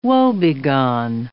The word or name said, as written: woebegone